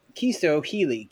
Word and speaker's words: Chisto Healy: so, with us today, we have Chisto Healy.